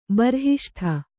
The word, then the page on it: ಬರ್ಹಿಷ್ಠ: harhiṣṭha